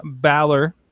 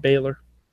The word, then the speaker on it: Balor: It is Finn Balor. – We haven't all been through this. – We have all been through this. – I have never pronounced it Baylor.